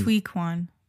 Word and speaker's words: Ke Huy Quan: It's H-S-U. – Yes. – So that's why I wanted to double check. Kehu Kwan. – I think it's Hui Kwan. – Hui Kwan? That's how you would pronounce it? – Well, I heard him say it.